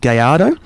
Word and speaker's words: Gallardo: Gallardo, inspired by the one... that is actually how it's pronounced. It's Spanish, my friend, you know.